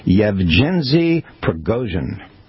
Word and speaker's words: Yevgeny Prigozhin: It's Yevgeny Prigozhin.